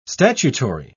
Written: statutory

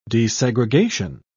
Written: desegregation

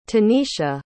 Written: taneisha

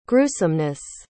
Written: gruesomeness

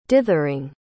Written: dithering

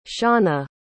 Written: shauna

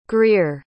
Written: grear

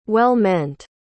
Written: well-meant

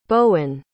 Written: Bowen

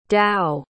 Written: dao

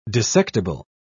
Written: dissectible

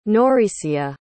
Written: nauricia

